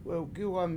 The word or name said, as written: wewgIwam